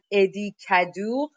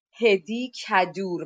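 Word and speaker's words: Hédi Kaddour: به زبان سخت فرانسه بهش میگن ادی کدوغ که اگر بخوایم به فارسی بخونیمش حالا با تلفظ فارسی بخونیمش میشه هدی کدور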